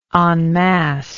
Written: en masse

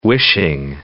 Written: wishing

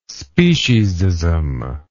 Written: speciesism

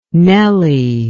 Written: Nellie

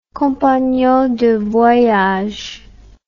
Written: compagnon de voyage

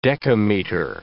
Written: decameter

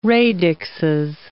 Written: radixes

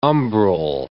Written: umbral

